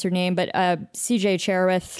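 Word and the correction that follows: Cherryh: Cherry. It's just Cherry.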